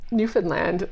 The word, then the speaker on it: Newfoundland: Like, Newfoundland is a... I don't even know if I said it right. – You did.